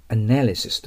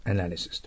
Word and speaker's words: analysis: 名詞はね、分析という名詞は、 analysis とアクセントがあります。 analysis と。